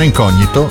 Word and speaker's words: incognito: Molti, per fare il figo, per anni gli hanno pronunciato incognito. In realtà si chiamano incognito.